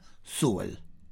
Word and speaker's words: Sewell: Se escribe Sewell, Sewell, pero todo el mundo lo pronuncia Sewell.